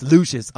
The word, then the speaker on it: Lucius: I always say Luscious, but it's Lucius on the show, but it's spelled Luscious, so I'm going to say Luscious every time, right?